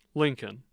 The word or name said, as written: Lincoln